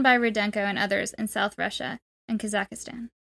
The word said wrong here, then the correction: Kazakhstan: Kazakhstan. – Kazakhstan, sorry, I added a syllable.